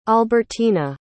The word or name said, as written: albertina